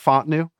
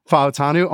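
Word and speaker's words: Fautanu: It's not Fontenu. It's, God dang it, Faotanu.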